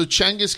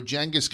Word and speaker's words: Genghis: In Mongolia, the G is always soft. – Yeah. – Apparently. But we're not in Mongolia. – I'm going to be honest. I thought you were really sleepy this morning just saying it wrong. I was like, large right off the top, just saying it wrong. – No, not at all. So Genghis Khan is, I think, how it's preferred.